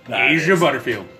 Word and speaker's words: Asa Butterfield: Asa Butterfield.